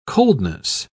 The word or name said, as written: coldness